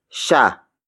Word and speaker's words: siya: The next word is sha. Sha. Sha. Sha is spelled as S-I-Y-A.